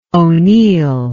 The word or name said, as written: O'Neill